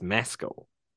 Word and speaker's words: Paul Mescal: Another great work from Paul Mescal. Did I say it right? – Yeah, like really quickly. I, have never Mescal. heard you. Um, well, it's just Mescal. There's no, it's not. There's not a prolonged.